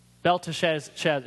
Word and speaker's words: Belteshazzar: I can never pronounce his name. Belshazzar. Belteshazzar. Yeah, there you go.